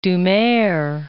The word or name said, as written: Doumer